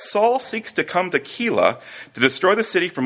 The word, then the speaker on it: Keilah: David comes to a city called, I think I'll pronounce it Keilah. K-E-I-L-A-H, right?